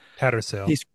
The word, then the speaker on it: Tattersail: Like the first hit that Taste Grail, am I saying that right? – Tattersail.